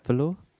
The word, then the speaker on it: velour: I paint pastel on velour. I would like... have I said that right? Velour? – Yes, velour.